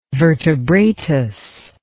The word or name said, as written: vertebratus